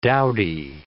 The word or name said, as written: dowdy